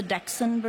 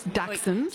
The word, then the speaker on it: Dachshund: So you'll have the Dachshund breeds, all the varieties of Dachshund. – That's how you say it. I've been saying it wrong my whole life. Thank you very much. Dachshunds.